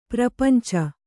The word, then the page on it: ಪ್ರಪಂಚು: prapancu